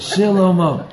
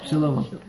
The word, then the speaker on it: Shlomo: That's the way to pronounce it. Shlomo. Shlomo. Go ahead.